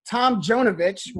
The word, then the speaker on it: Tomjanovich: We have Rudy Tomjanovich, which I'm not – Matt, do you know if I'm pronouncing that right? – That's correct.